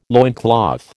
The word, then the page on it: loincloth: American English